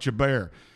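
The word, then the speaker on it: Chebar: Some people may call it Chabar, I call it Chabar. Chabar, Chabar, it was by that river that's C H E B A R.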